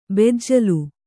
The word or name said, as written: ಬೆಜ್ಜಿಲು